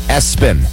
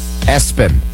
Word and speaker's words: ESPN: And then there's the kid who's named after the American Sports Channel, spelled ESPN, but it's apparently pronounced Espen. Espen, but spelled ESPN.